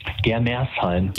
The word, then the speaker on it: Germersheim: Heißt das richtig? Germersheim? Habe ich es richtig gesagt? – Germersheim. – Germersheim.